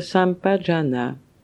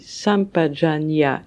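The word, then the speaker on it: sampajañña: Comprendre clairement, à fond, complètement, c'est la signification de Sampajana. L'expression Sampajana, qui peut se prononcer Sampajanya également, se divise en trois parties. Sam, Pa et Jana ou Janya.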